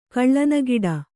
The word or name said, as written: ಕಳ್ಳನಗಿಡ